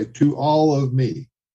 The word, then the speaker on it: Tuolumne: Pronounce it this way. To all of me.